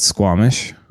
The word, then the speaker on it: Squamish: How do you say the American version of Squamish? Is it Samish? – No, it's Squamish.